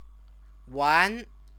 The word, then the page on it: wan: Chinese